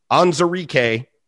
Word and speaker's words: Onwuzurike: A lot of people have been saying Onwuzurike. Looked up the pronunciation. Onwuzurike, defensive lineman from Washington.